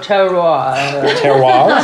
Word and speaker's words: terroir: Terroir? – Terroir. – That's exactly how it's said.